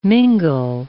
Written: mingle

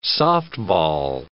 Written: softball

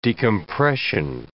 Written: decompression

